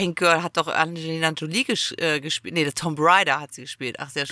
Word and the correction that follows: Tomb Raider: Es heißt ja Tomb, Tomb Raider sogar. Ich habe es, glaube ich, 20 Jahre falsch gesagt. Es heißt Tomb Raider. Nicht der Tom.